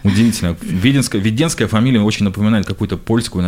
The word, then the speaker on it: Веденская: А Веденская, я правильно ударение Веденская. – Ставлю? Веденская. Веденская, да? Веденская.